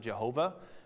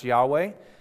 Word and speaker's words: Yahweh: They originally pronounced it Jehovah, which a lot of y'all have heard. They've figured out now that it actually should be pronounced Yahweh.